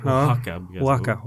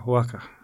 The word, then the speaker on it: Oaxaca: till Oaxaca brukar jag uttala det. Men jag kanske uttalar det fel. – Det är svårt att uttala det. – Ja, det är det, va? – Oaxaca. – Oaxaca. Ja, just det.